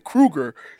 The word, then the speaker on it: Krueger: You said cougar. – Cougar? Like John Cougar Mellencamp? – Freddy Krueger? – Cougar. Freddy Krueger.